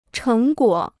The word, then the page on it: 成果: chéng guǒ